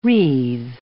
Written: wreathe

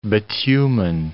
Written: bitumen

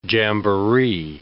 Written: jamboree

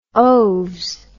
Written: oaves

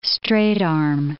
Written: straight-arm

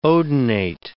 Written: odonate

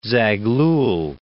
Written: Zaghlal

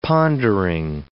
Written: pondering

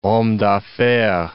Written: homme d'affaires

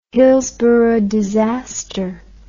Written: hillsborough disaster

